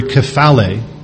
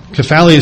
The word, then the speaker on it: kephale: That word head, used there, and as we just read in 1 Corinthians 11, is translated from the Greek word kephale. Kephale is spelled K-E-P-H-A-L-E, kephale. And the primary word that that's taken from, kapto, K-A-P-T-O, is actually is used or translated as a seizing or an embracing of something or someone.